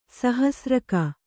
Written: ಸಹಸ್ರಕ

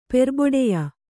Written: ಪೆರ್ಬೊಡೆಯ

